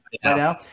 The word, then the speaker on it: Vidal: Kamani, is it Vidal or Vidal? How do you say his last name? – Vidal.